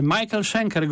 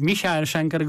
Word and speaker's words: Michael Schenker: Michael Schenker Group lub Michael Schenker Group, zależy kto jest, kto jak wymawia, czy jest Niemcem, czy Anglosasem.